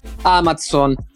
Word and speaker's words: Amazon: precisiamo la pronuncia corretta di Amazon, perché dobbiamo essere precisi, noi qua per la precisione siamo i fan della precisione, quindi Amazon. Amazon che ha creato un nuovo,